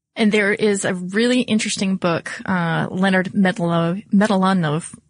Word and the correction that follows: Mlodinow: Excuse me. Let me try that again because I'm sure that at least his family would appreciate that I pronounced it correctly. But Leonard Mlodinow.